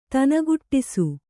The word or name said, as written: ದನಿಗೂಡಿಸು